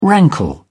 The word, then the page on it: rankle: British English